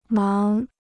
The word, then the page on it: 忙: máng